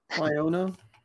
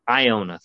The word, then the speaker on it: Iona: Like, Iona? Is that how you pronounce that? They lost to them. – Iona? – Iona, thank you. I didn't know how to pronounce that.